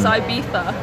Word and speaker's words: Ibiza: Yeah, because you guys call it Ibiza here, don't you? – Yes, we do. – Well, like the Spanish pronounce their Z's as T-H's, so it's Ibiza.